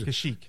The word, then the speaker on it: Kashyyyk: I also think the majority of movies either going to take place on Endor or Krushik, Chewie's home planet. – Okay. – Because Kashyyyk.